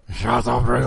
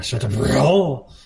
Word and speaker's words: Chateaubriand: That's yeah, you got to say it like that. – Chateaubriand. – Oh. Yeah, Chateaubriand. Because I think it is a French word.